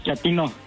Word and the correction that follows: chatino: No, chatino. – Es como chato, ¿verdad? Chatino. – Sí, azunero, como chato, chatino.